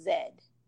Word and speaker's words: Z: And the letter Z is pronounced Zed.